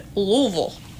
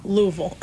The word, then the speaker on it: Louisville: And Louisville.